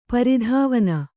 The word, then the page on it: ಪ್ರಧ್ವಾನ: pradhvāna